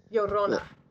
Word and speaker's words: Llorona: Llorona.